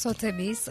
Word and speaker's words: Sotheby's: Sotheby's evet.